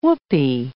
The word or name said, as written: whoopee